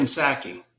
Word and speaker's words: Psaki: Psaki? – Oh, Jen Psaki. – Psaki. Yeah. It started with a P. Sorry. I'm not supposed to pronounce it.